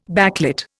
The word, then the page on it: backlit: Indian